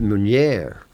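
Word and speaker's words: Meunier: It's called Meunière. – Meunière. Yes. Can you pronounce it? – Meunière.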